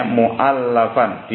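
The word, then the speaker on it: مؤلفا: Perhatikan bacanya mu'allafan. Di fathalamnya isim maf'ul ya. Beda dengan mu'allif. Kalau mu'allif isim fa'il, penyusun, yang menyusun. Kalau mu'allaf itu isim maf'ul yang disusun.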